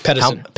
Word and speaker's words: Pedersen: Pedersen.